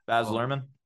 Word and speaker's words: Baz Luhrmann: I'm really not a Be Buzz Larman fan. Is that how you say it? – Baz Luhrmann.